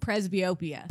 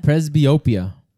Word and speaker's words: presbyopia: Presbytopia? Is that what you called it? – Presbyopia. – Presbyopia.